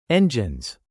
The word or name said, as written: engines